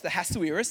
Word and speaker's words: Ahasuerus: We read, if you just go to Esther chapter 1, verses 1 through 3, we read, "Now in the days of Ahasuerus," that is how that name is pronounced, Ahasuerus.